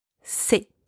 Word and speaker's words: ces: C'est, C-E-S, c'est. If the noun is plural, and whether it's masculine or feminine, it does not matter.